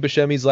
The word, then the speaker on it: Buscemi: He gets introduced as Buscemi, but when the topic gets brought up, Buscemi admits that while that pronunciation is correct, so is Buscemi.